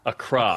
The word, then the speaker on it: Accra: Accra or Accra. Accra. – Accra. That's Accra. the native pronunciation. Yeah. We always say Accra in Accra, the U.S., right? But Accra. Yeah.